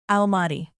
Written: almaty

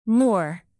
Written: moor

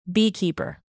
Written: beekeeper

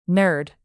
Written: nerd

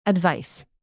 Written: advice

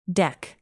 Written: deck

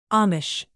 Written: amish

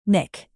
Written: nick

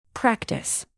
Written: practise

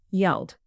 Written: yelled

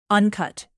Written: uncut

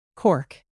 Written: cork

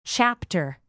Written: chapter

